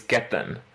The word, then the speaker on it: Ketan: So the original Gujarati pronunciation of my name is Ketan, which you sort of say in like a slight Indian accent.